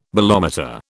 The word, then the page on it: bolometer: British English